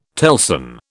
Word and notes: telson: British English

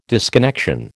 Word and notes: disconnection: American English

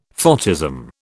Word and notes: photism: British English

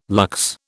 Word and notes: lux: British English

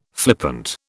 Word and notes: flippant: British English

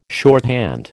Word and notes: shorthand: American English

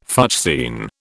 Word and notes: fuchsine: British English

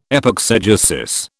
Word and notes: epexegesis: British English

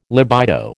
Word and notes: libido: American English

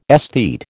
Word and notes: esthete: American English

